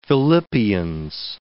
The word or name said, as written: Philippians